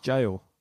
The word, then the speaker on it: Gaol: Is it Gaol? – I think it's just pronounced jail. – Oh, really? Oh, G-A-O-L. Yeah. Really? – It's the Gaelic spelling of jail.